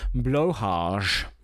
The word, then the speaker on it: Blåhaj: There, there's an accent over the first A. B L A with an accent, H A J. Stylized as Blage. Swedish pronunciation is Blåhage.